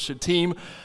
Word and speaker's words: Shittim: But the proper pronunciation, the I is long. It carries a long kind of E sound, usually in Hebrew, so it's Shittim.